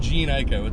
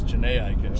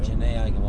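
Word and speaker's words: Jhené Aiko: It's not Jean Ico, it's Janae Ico. – Janae Ico, all serious.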